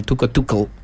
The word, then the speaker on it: Tuchel: Tuchel, nunca lo había imaginado así, mencionado el apellido del técnico alemán, pero a partir de ahora me queda el Tuchel. – Es como lo pronuncian los ingleses.